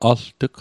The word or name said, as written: alt'g